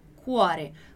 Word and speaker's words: cuore: Então, quando a gente tem um R numa palavra, a gente vai ler com essa pronúncia, cuore, cuore. Como na palavra arara no português, né? A gente leria assim. É o mesmo som.